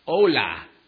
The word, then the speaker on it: hola: Well, if you're really bad with names like I am, you can just wave your hand and yell out hola, H-O-L-A, which means hi. It's the universal, casual Spanish greeting. Hola, H-O-L-A. and the H is silent.